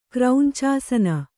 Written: ಕ್ರೌಂಚಾಸನ

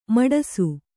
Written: ಮಡಿಸು

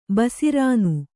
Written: ಬಸಿರಾನು

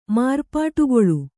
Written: ಮಾರ್ಪಾಟುಗೊಳ್ಳು

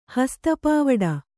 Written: ಹಸ್ತಪಾವುಡ

